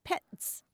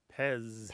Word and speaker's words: Pez: And in German, that's pronounced Petz. – Mm-hmm. – But in English, we pronounce it Pez.